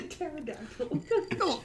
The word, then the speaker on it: pterodactyl: It's a pterodactyl. – Well, pterodactyl, pterodactyl. P is silent in it, I guess.